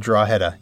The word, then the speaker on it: Drogheda: He said Drogheda, yep.